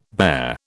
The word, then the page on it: bare: British English